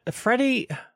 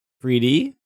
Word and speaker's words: Friede: There's not Freddy. Because the I is there? – Yeah. Mm-hmm. The I should be after the D. – Freed. And Freed is a word. – Mm-hmm. I mean, it is spelled F-R-I-E-D-E. – Freedy? – I'm going to go with Freed.